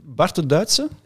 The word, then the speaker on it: Bart de Duitse: Is, Bart de Duitse. Ja, dus ik, zeg, ik zal zelf zeggen, dan weet hoe dat uitgesproken is.